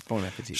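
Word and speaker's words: bon appétit: She writes, "You have a good humor about the grammar police, or I wouldn't dare say this, but you mispronounce bon appetit. A final T is silent in French. – Okay. Mm-hmm. – An E on the end would allow the consonant to be pronounced.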